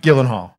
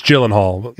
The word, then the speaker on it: Gyllenhaal: You were saying hard G, Gyllenhaal. – I was saying Gyllenhaal, and it's Gyllenhaal.